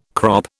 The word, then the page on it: crop: British English